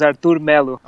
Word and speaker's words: Arthur Melo: Actually, the pronunciation of Arthur's name is just Arthur. There's no big secrets. It's Arthur Melo. – Okay. – It's not Arthur.